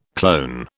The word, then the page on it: clone: British English